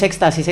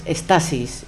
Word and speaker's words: estasis: Y es que todo el mundo es éxtasis, éxtasis, o no, es estasis interrumpida, es, estasis interrumpida y es la interrupción.